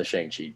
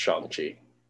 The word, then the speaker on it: Shang-Chi: Olivia, what did you think about the Shang-Chi trailer? Or sorry, apparently it's pronounced Shang-Chi.